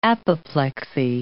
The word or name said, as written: apoplexy